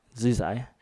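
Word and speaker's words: Zhu Xi: Zhu Zai.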